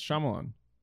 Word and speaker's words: Shyamalan: Yeah, it's Shyamalan. Shyamalama ding dong. – Oh my god, I gotta pee. – Yeah, it's Shyamalan.